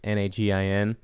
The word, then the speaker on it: Nagin: It's N A G I N. – Uh, Nagel, right? N A G I N. Nagin. Nagin. Yeah.